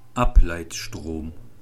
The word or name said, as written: Ableitstrom